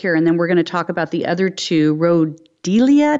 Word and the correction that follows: Rhodiola: Did I say that right? Rhodiola. – Rhodiola.